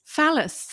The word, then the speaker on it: phallus: And then she says, so is palace. – Phallus. – Phallus. – Phallus, yeah. – Well, it's got a P there. – Yeah, it's a silent P.